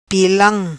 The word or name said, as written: Pilam